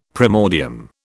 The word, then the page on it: primordium: British English